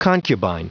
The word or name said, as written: concubine